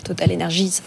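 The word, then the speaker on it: TotalEnergies: Je note que vous prononcez bien le S derrière Total Energies.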